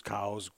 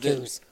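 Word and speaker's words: Coues: I'm going to get feedback from somebody that I'm saying it wrong, and they want it said coos, cows. – No, it's pronounced coos.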